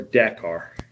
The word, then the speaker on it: Dakar: Do you pronounce it Dakar or Dakar? – I usually say Dakar. – Dakar? – Yeah. – It sounds more European, but, right? – Yeah, I don't know.